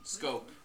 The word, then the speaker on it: Schoop: Jonathan Schoop. – Scope. It's pronounced.